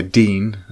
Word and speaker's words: Digne: He had it where, from an FPL uh, standpoint, from the Liverpool Everton game, he had Digne, which is, uh, uh, we know how to pronounce it now. It's actually Luca Dean.